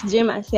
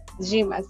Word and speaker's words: Gimas: It's Jimas, yeah. – Basically. Okay, the spelling, my dear. – Okay, it's G, but it's okay. It's pronounced as J, but it's G. Gimas.